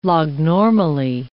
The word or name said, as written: lognormally